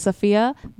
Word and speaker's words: Safiya: Like, my name is Sophia, but people think it's spelled Sophia.